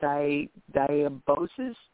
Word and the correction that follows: dysbiosis: The flora. – Dysbiosis. – Dysbiosis. Say it one more time.